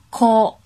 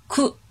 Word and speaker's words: 口: 我们来看口的音读，它有两种发音，一种是 ko，一种是 ku。